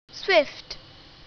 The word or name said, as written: swift